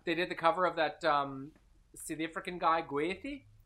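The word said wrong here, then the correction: Gotye: Gautier? – It's pronounced like Jean-Paul Gaultier.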